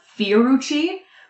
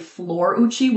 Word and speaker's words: Florucci: We were saying it as Fiorucci, but it's actually Florucci with an L.